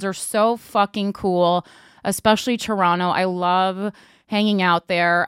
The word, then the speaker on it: Toronto: Toronto, I'm making my grand return to Canada February 17th and 18th.